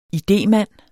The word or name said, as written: idémand